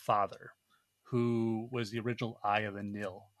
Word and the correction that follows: Nile: Of the Nile.